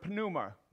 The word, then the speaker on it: pneuma: So it comes from the Greek word pneuma. They pronounce the P on the front of the N. We don't, right?